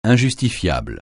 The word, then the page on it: injustifiable: francais